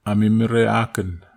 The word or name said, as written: amimera aken